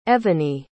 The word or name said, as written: Evony